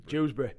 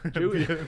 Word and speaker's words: Dewsbury: Is that how you say it? Dewsbury? – Dewsbury. – Bury? – Just Dewsbury, there.